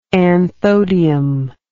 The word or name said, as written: anthodium